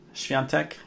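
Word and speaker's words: Świątek: Swiatek, yeah.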